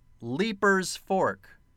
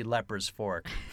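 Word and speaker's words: Leiper's Fork: But it's spelled L-E-I-P-E-R-S. And I thought, at first I thought, okay, I know that it's probably Leaper's, but it could kind of be Leper's Fork.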